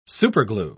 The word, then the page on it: superglue: British English